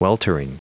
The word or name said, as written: weltering